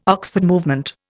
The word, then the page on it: Oxford Movement: Indian